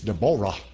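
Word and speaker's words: Deborah (דבורה): A lot of women named Deborah, but in Hebrew it's Deborah, because the accent's on the Ah, Jehovah, the name's attached to it.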